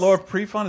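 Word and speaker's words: Laura Prepon: Laura Prefon, is that how you say her name? – Yeah.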